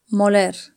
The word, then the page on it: moler: Spain Spanish